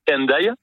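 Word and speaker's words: Hendaye: Et d'ailleurs, Andailles s'écrit aujourd'hui H-E-N, donc peut-être qu'en français on dirait plus facilement Andailles. – Ah oui. – Mais c'est bien Andailles qu'on dit. On a bien conservé le son ou la prononciation qui était celle de l'orthographe du XVIe siècle.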